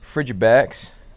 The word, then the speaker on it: Frigibax: We got a Frigibax.